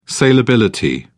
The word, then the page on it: salability: British English